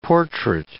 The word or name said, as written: portrait